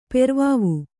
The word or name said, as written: ಪೆರ್ವಾವು